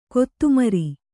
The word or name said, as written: ಕೊತಮರಿ